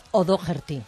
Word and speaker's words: O'Doherty: Alex O'Doherty. Decía, con acento en la D.